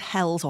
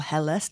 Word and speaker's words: Helles: In fact, the word Hells or Helles, depending how you pronounce it, means light in German.